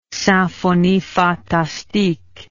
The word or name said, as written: Symphonie fantastique